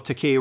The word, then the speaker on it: Takei: George's last name is pronounced with a K sound, not like Kublai Khai. That is something we did. I flipped and flopped between Takei and Takai and somehow fell on the wrong side of it. But as you can see, I've corrected it in this comment section. The second syllable of Takei rhymes with the letter K.